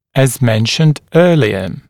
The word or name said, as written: as mentioned earlier